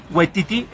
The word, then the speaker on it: Waititi: y probablemente estoy pronunciando mal su nombre. – Waititi, la última es Waititi.